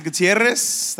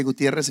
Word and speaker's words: Gutierrez: My name is Isaac Gutierrez, that's my name. Like I said, you can say Gutierrez if you can roll your R's.